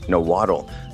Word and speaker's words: Nahuatl: Axolotl's name originates from a Udo-Aztecan language called Nahuatl, or as other people know it as Nahuatl. Though the traditional pronunciation of the last two letters, TL, is more of a S sound or a K sound.